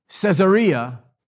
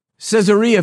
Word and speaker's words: Caesarea: If you put the emphasis on the wrong syllable, any word sounds funny. So if you don't say Caesarea, but if I say Caesarea,